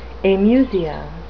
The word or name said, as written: amusia